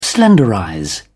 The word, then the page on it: slenderize: British English